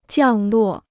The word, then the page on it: 降落: jiàng luò